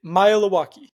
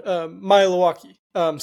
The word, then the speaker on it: Maealiuaki: It's easy if you kind of break it up into three kind of syllables and say it fast. – Maealiuaki. That's it? – That's it. Maealiuaki.